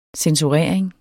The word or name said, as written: censurering